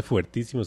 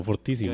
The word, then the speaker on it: fortísimo: Que usted sabe que no se dice fuertísimo, sino fortísimo.